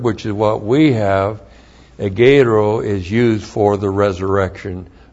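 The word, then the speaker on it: Egeiro (ἐγείρω): And then we have Egeiro. E-G-E-I-R long O. Egeiro.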